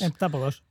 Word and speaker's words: heptápodos: Bueno, los dos no me acuerdo cómo se pronuncia, eh, heptópodas, eh, los bichos. Heptápodos, de momento son los bichos.